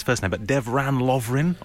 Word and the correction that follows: Dejan Lovren: of Dejan Lovren, Liverpool centre back.